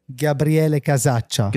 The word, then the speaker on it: Gabriele Casaccia: Did I say your name correctly? – Gabriele Casaccia. – Casaccia. – Perfetto.